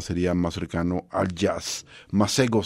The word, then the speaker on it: Masego: Masego, como lo se escribe, como lo dije.